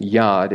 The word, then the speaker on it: yod: It is pronounced yod in Hebrew, though.